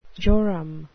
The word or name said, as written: jorum